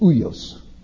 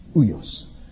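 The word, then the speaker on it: uios (υἱός): And it's said uios, uios.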